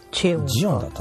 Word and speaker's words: Xeon: スペック的にはですね、 CPU が、インテルの、これなんて読むんだったっけ？ – ん？ – インテル、これなんて読むんだったっけかな？ XEON。ジオン。ジオンじゃなかったかな？ – ゼ？ – 確か読み方が。 – ジオンかゼオンかジオンかチェオンか、ジオンだったと思う。ジオンの W カスケードレイライク X プロセッサーたどたどしい。